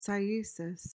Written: cyesis

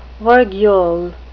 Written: virgule

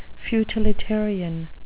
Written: futilitarian

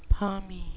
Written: palmy

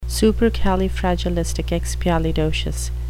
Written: supercalifragilisticexpialidocious

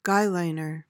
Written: guyliner

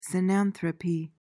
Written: cynanthropy

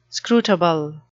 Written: scrutable

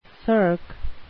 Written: cirque

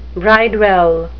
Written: bridewell

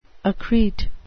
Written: accrete

